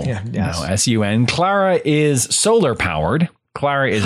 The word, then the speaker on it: Klara: And Klara, litheads, just so you have a clear vision in your mind of this, it's spelled K-L-A-R-A, not C-L-A-R. It's Klara. It's kind of like good. A Germany type of thing. – Yeah, Klara. – And S-U-N or S-O-N? – And the sun, yes.